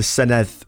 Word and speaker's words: Senedd: But unfortunately, a lot of people on the news can't seem to gist the fact that it's the Senedd with a th, not Senedd with a hard D.